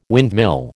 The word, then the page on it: windmill: American English